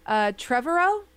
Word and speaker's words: Trevorrow: Colin Trevorrow? – That looks good.